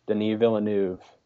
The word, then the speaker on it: Denis Villeneuve: Denis Villeneuve. That's how his name's pronounced, right? – Good job.